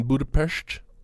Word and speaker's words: Budapest: We did it in Budapest, which is the correct pronunciation for Budapest, by the way. All these years, been saying it wrong.